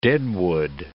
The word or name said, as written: deadwood